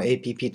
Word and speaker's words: APP: 看到学韓语的 APP。 APP というのはアップですね。アプリのこと。中国語ではですね、 APP そのままアルファベットで APP と書いて、読み方も APP と読みます。アップとは読みません。